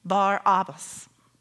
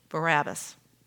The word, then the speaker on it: Barabbas: So the notorious murderer, insurrectionist, robber's name is pronounced Barabbas, even though through the generations, the common pronunciation has become simply Barabbas.